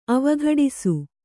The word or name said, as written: ಅವಘಟಿಸು